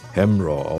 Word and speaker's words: Hamraw: Oder das Stück Hamraw, das klingt amerikanisch, ist aber eigentlich eine Erhebung, die bei uns vom Nachbarort Hemrein heißt, aber bei uns im Fronhäuser Platt in der Sprache Hamraw ausgesprochen wird.